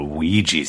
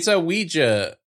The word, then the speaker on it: Ouija: I think it should be Ouija, but I always say Ouija as if it was spelled W-E-E-G-E-E.